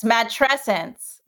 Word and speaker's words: matrescence: You have this great word for the transition to motherhood called matrescence. Am I even pronouncing that correctly? – Yes, matrescence. It's like adolescence. That's how I remember how to pronounce it.